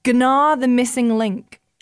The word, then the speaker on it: Gnar: I'm pretty sure the G is silent. – Gnar.